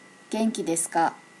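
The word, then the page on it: genkidesuka: native Japanese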